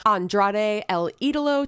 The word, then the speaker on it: Andrade El Idolo: Also, let me say it correctly just in case you don't know how to pronounce. Andrade El Idolo taking on Buddy Matthews in a ladder match for Andrade's mask, which House of Black stole a couple weeks ago.